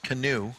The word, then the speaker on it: Kanu: it's actually shaped like a kayak, and it's pronounced canoe.